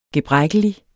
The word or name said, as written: gebrækkelig